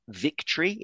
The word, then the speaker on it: VKTRY: The carbon fiber prefabricated insole they were given, I believe, is pronounced Victory. It's VKTRY.